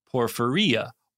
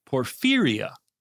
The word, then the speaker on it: porphyria: Although JD has pronounced it correctly in a previous episode, because this was in a previous episode, both JD and Dr. Cox pronounce acute intermittent porphyria incorrectly. They pronounce the last part porphyria, while it is pronounced porphyria.